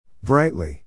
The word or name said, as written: brightly